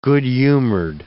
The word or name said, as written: good-humored